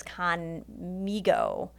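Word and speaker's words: Khanmigo: No, I always want to say Khan Mingo, but it's not. It's Khan Mingo. Is like a Amigo? – Amigo, okay.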